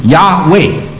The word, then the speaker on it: Yahweh: 对，这个字 YHWH 发音发成 Yahweh 啊。